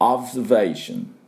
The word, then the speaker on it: observation: で、 V の音 V Vation、こんな風に発音していきます。で、オブ、これは最初は B でその後が V Vation、オブのところは V B ね、でその最後が、 Vation のところは V V ですから、この使い分け、ここがポイントです。 Observers Vation で私の後で発音いきましょう。 Observation Observation バッチリいきました。